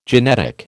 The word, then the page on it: genetic: American English